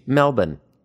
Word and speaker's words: Melbourne: Melbourne, just think of B-I-N, M-E-L-B-I-N, that's how you should say it.